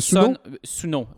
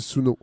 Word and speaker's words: Sunn: Euh, Suno, S-U-N-N, euh, suivi d'un espace et de trois parenthèses. – Oui, voilà. Eux disent que ça se prononce Sun et que le nom vient d'un amplificateur qu'ils utilisaient des années. Mais moi, le gars de l'oblique, il m'a dit c'est Suno.